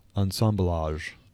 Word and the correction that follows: assemblage: That's, God damn it. – Well, it's just so pretentious and ridiculous. It's assemblage.